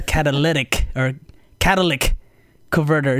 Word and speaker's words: catalytic converter: So I started calling it the Cadillac converter, but it's actually the catalytic converter.